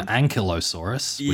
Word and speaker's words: Ankylosaurus: It is actually an Ankylosaurus, which I mispronounced last week even.